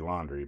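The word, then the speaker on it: Laundrie: It's not spelled laundry like your dirty laundry, but I don't know. It's an interesting last name. It's pronounced Laundry, I guess.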